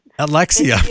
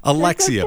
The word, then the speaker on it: Alexia: I may have mispronounced your name, like John Travolta. – It's okay. It's Alexia. – Alexia. Oh my gosh. I'm so sorry. Alexia, okay.